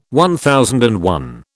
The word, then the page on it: MI: British English